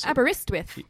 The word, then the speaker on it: Aberystwyth: Aberystwyth.